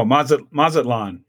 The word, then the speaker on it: Mazatlan: The Matalazan narrowmouth toads. – Oh, Mazatlan. Yeah, Mazatlan narrowmouth toads. I knew I was not pronouncing it right.